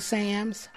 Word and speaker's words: Sams: Paula Sams. The last name is spelled S-A-M-S.